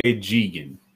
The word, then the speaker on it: Jigen: It's called, his name is Daisuke Jigen.